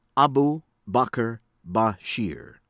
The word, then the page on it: Abu Bakar Bashir: American English; from Indonesia